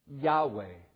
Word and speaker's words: Yahweh: You say, how do you pronounce it? It is pronounced Yahweh.